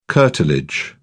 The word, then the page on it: curtilage: British English